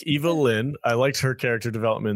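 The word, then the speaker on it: Evil-Lyn: You're saying her name wrong, man. – It's Evil Lyn. I didn't say it wrong. It's Alex who's saying.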